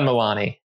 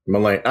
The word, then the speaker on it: Mulaney: That I like John Milani. – Am I saying that right? Am I saying his name right? No, Milani. Mulaney. I would say Mulaney. Okay, M U L A N E Y, right?